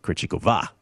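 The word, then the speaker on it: Krejčíková: So I'm on Krejcikova against, or I guess it's Krejcikova is how you actually pronounce that, against Sakkari.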